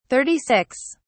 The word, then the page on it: 36: British English